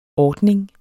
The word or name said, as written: ordning